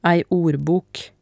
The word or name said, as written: ei ordbok